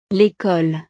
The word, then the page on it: l'ecole: frances